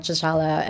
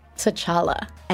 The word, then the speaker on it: T'Challa: Beyond, you know, your main couple, T'Challa and I said that wrong. T'Challa and Nakia.